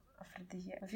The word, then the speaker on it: афродизиак: Э, афродизиак. Видите, как я подкована в этом. Как правильно слово произносится? – Афродизиак.